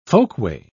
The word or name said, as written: folkway